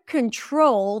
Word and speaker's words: control: In the word control, the primary stress is on the second syllable. Control, control, control. We don't say control, but control.